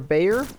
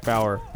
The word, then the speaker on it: Bauer: Jack Bauer or Bayer? – Jack Bauer. – Bauer? – From 24.